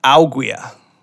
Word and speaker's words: ouguiya: The word is augia. Augia. You spell it O-U-G-U-I-Y-A.